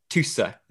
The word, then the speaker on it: Tusse: Husse? Am I pronouncing Husse. That one? You tell me if I'm pronouncing that one correctly. Is that good? – Okay. Tusse. – Is that okay? – Tusse, yes. Perfect.